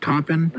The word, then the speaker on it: Taupin: And he's worked, he does the music, and for the most part, I'm not quite sure how to pronounce Bernie, is it Taupin? – You've got it, that's right.